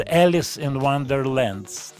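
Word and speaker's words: Ellis in Wonderland: Слово «Эллис» в его фамилии пишется через E-L-L-I-S, «Елолис». А пластинка называется «Елис in Wonderland». Читается «Элис in Wonderland».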